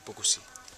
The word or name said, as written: bukusi